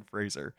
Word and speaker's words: Fraser: And he says, like, you know the word razor? It rhymes with razor. Brendan Fraser.